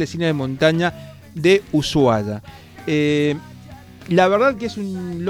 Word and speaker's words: Ushuaia: Y seguimos con el cine, el Festival Internacional de Cine de Montaña Ushuaia, así es, y así se pronuncia, según nos dijo una de las organizadoras programas atrás,